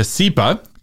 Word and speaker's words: CIPA: And I just want to say thank you to CIPA, which is how the acronym is pronounced, for helping make the blog post version of this episode possible.